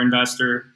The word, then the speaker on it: InvestSTR: And I think there's really two things you would compare investor, which I don't know if you guys know, but our company is called Investor, and it's spelled Invest STR, so invest in short-term rentals.